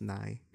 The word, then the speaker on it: Nighy: Nighy? Sorry, I thought it was pronounced Nye. Like the scientist. – I've heard it pronounced either way, but yeah, he's the British guy, right?